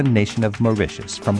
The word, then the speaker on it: Mauritius: Let's go to Mauritius. Mauritius, it rhymes with delicious.